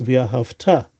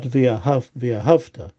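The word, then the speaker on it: V'yahavta (וְאָהַבְתָּ): Okay, and also there the emphasis is on the future syllable, should be pronounced V'yahavta, Et Hashem Lokecha, not V'yahavta. Okay, not the emphasis on the previous syllables, because that sounds like you loved Hashem in the past, but not anymore, which is obviously we don't want to convey that.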